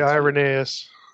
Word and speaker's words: Irenaeus: And as the early church father, please correct my pronunciation, Irenaeus, or I don't know. – I always say Irenaeus. – Irenaeus.